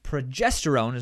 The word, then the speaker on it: progesterone: And the second half is when progesterone, is that how you say it? – Yep.